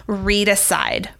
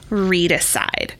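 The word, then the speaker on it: Readicide: For all of you out there listening in podcast land, Read Aside by Kelly Gallagher. It is life-changing. You spell it like homicide. It's R-E-A-D-I-C-I-D-E. Read Aside.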